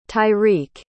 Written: tyrik

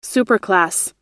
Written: superclass

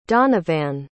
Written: donavan

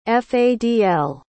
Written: Fadl

